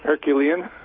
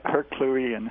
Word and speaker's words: Herculean: It would just be, Herculean task. How do you say that? – Herculean. – Yep, Herculean. – Herculean. Yes.